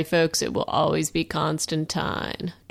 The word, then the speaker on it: Constantine: We're going to be reviewing the pilot episode of Constantine, even though, I guess, due to American following, it's Constantine per the series. But I'm calling it Constantine. Rhymes with Valentine.